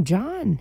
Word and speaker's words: Jon: It is spelled J-O-N. John. His name is John.